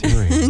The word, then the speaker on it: Uranus: Yes, it is Uranus. – Okay. Just wondered. – Uranus. Uranus. – That's right. They changed the name They changed the pronunciation. They changed the emphasis on the syllables.